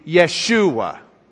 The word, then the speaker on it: Yeshua: Now, if we were pronouncing it as Hebrews, the Hebrews didn't pronounce the J. It was a yuh sound to them. So it'd be Yeshua instead of Joshua.